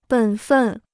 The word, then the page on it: 本分: běn fèn